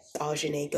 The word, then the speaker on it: Ausuni: Follow me on social media, Ajane Ghost, and my name is spelled AUSUNI.